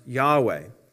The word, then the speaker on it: Yahweh: Now that refers to, in the original Hebrew, God's name, which is commonly pronounced Yahweh.